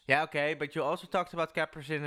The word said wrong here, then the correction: capsaicin: and cheese. – Capsaicin. – I finally kind of pronounced it right.